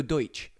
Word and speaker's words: Deutsch: Well, I mean, he is called Oscar Dutch, the guy who made the Odeon. No, Deutsch. Oscar Deutsch. – So? – Which means German, right? – Deutschland is Germany. – It's spelled D-E-U-S-T-C-H.